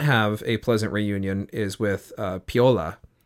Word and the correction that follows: Paoli: Am I saying that right? – Paoli, excuse me. – Paoli, I think.